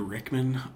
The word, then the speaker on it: Rickman: Peter Rickman, R-I-C-K-M-A-N.